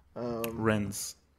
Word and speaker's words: Rennes: Rennes. – I don't think the S is pronounced.